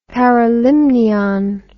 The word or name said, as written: paralimnion